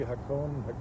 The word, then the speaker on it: Hakone: Hakone, yes.